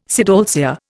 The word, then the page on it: sidalcea: Indian